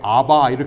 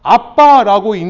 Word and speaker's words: ABBA: 우리 미국 분들은요, 이 쌍비읍이 안 되니까 어떻게 해요? 아바 이렇게 읽거든요. 근데 우리 한국 사람들은 쌍비읍 잘하죠? 빨리빨리 잘하죠? 우리는 어떻게 읽어요? 아빠라고 읽는 겁니다. 이게 정확한 발음이에요. 아람어에, 원어에.